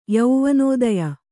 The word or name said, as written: ಯೌವನೋದಯ